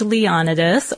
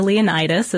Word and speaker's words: Leonidas: A king named Leonidas, or Leonidas, as the movie, as they pronounce it in the movie,